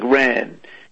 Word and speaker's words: wren: Wren, pronounced like ran.